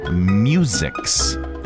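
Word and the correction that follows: muzjiks: Muzhiks. – Muzhiks. Okay. So it's not musics. It's muzhiks.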